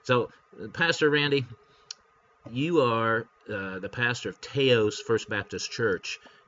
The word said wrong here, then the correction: Taos: It's actually Taos. It rhymes with house.